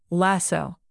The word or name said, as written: lasso